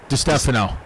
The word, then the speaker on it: DiStefano: DiStefano. – DiStefano. – Yeah, there was Mike DiStefano, and yes, now I'm Chris DiStefano.